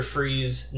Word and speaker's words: Fries: I like that it's spelled like fries. I always read it as fries in my head. – F-R-I-E-S.